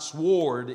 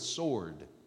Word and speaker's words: sword: That's how you pronounce that. Not sword, it's sword. The W is silent. Sword, remember that.